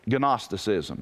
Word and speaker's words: Gnosticism: Notice the letter in front of it. It's silent. Don't pronounce the G. You'll look stupid. Gnosticism. Mm-mm. It's like pneumonia. Don't pronounce the P. Pneumonia. You know, it's pneumonia. P silent, G silent, and Gnosticism.